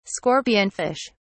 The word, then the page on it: scorpionfish: British English